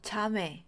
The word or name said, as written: 자매